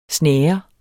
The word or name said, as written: snære